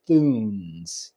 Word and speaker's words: Thunes: One from Payhawk and the other from Thunes. Did I pronounce it right? Thunes. – Yes, there's a few different versions of it.